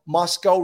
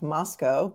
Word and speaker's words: Moscow: town of Moscow, pronounced not like the Russian capital, but to rhyme with Costco.